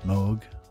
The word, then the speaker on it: Moog: We did hear the title track, Moog Sensations. Moog. Don't want the pronunciation police after me.